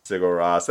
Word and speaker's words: Sigur Rós: Sigur Rós. Did I say that right? – Sigur Rós. That's how I've been doing it.